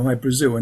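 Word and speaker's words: Hy-Brasil: People pronounce it Hi, Brazil. And it's Brazil. Okay. – yeah. But it's Hi, Brazil, because that's how the Irish would pronounce it, because it's an, okay.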